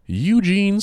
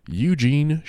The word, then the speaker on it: Eugene: Eugene Shepherd. I'm going to say his name again because I said it weird. Eugene Shepherd. – You pluralized his first name.